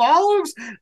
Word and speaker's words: Olive: Yeah, because I mispronounced his name during the interview, but then Chad remembered that his name sounds like olives.